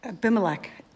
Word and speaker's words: Abimelech: And Abimelech, king of Gerar, sent and took Sarah. But God came to anyone want to pronounce that for me? – Abimelech.